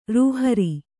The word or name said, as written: ರೂಹಾರ